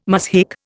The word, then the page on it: muzhik: Indian